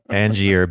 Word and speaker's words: Angier: So I picked, and it's spelled A-N-G-I-E-R, Angier, Biddle Duke, born November 30th, 1915.